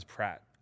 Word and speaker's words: Pratt: My real last name is Pratt. – Okay. – And it rhymes with fat.